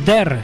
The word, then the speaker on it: Dare: Ellos son Dare o Der, si quieres lo pronunciamos un poquito mejor. We'll be right back.